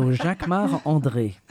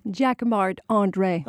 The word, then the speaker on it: Jacquemart-André: You say it like a Frenchman. – Of course, because I'm French. So Jacquemart-André. – Say it so we can understand it. – Jacquemart-André.